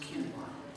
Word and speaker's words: quinoa: Help me understand why that word is pronounced kinwa.